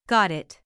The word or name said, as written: got it